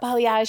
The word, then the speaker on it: balayage: But what is balayage? Am I even saying that right? Like, what is that? – Yeah, yep, balayage.